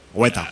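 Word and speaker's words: Weta: Hueta. – Hueta. Beta, para pronunciar la W como hacemos aquí, ¿no? – Sí.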